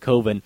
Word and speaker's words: Coven: He pronounces it, Coven.